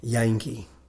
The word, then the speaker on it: Jantjie: So it's spelled J-A-N-T-J-I-E. – Wow. – Yankee is the song's name. – I wouldn't have guessed a T in there.